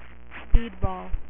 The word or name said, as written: speedball